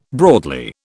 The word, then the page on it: broadly: British English